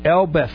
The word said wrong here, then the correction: Elsbeth: So it's not Elbeth. – It's Elsbeth. – Elsbeth.